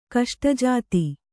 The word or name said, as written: ಕಷ್ಟಜಾತಿ